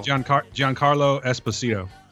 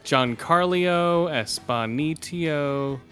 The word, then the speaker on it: Giancarlo Esposito: Godario. – Giancarlo Esposito. – God damn. Giancarlo Esposito.